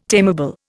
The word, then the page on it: tameable: Indian